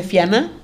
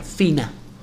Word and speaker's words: Fianna: En el libro dice Fianna, pero se pronuncia según mis parientes irlandeses, porque los Fianna son de Irlanda, se pronuncia Fina.